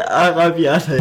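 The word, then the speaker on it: Arrabbiata: Also wir essen ja eh heute Alavira. Alla. äh, Alla. er kann die Nudel, er kann das Nudelgericht nicht aussprechen. Al, al Arabiata, ja.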